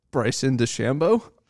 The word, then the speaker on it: Bryson DeChambeau: Bryson DeChambeau.